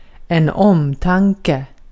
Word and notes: özen: turkiska

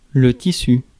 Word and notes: material: American English; [mə.ˈtɪəɹ.i.əl]

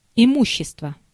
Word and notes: matériel: [ma.te.ʁjɛl]; France

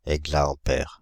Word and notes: exaampère: [ɛɡ.za.ɑ̃.pɛʁ]

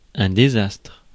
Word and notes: désastre: France; /de.zastʁ/

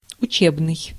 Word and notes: учебный: /ʊˈt͡ɕebnɨj/; utšebnyi

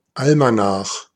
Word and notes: Almanach: /ˈalmaˌnax/; [ˈalmaˌnaχ]

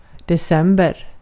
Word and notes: joulukuu: /ˈjou.luˌkuː/

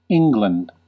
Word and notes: England: /ˈɪŋɡlənd/

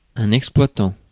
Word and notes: exploitant: France; /ɛks.plwa.tɑ̃/

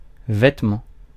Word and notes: vêtement: France (Paris)

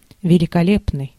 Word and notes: gorgeous: English

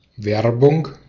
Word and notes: Werbung: /ˈvɛʁbʊŋ/

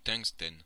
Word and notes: tungstène: France (Normandie); [tɛ̃k.stɛn]

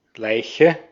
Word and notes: body: /ˈbɒd.i/; [ˈbɒd.i]; [ˈbɑɾ.i]

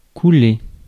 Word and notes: shed: /ʃɛd/

